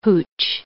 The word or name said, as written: hootch